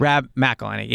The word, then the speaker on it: McElhenney: Rob McElhenney, yeah.